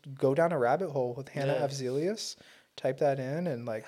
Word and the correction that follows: Afzelius: Yeah, how do you say that? – Avzelius. Half. – Af. Oh, A F. – Afzelius.